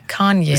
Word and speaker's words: Kanye: Kanye. Kanye. – Kanye, finde ich, sagst du super. – Ich glaube, die Betonung ist auf der ersten Silbe.